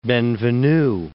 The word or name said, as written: Ben Venue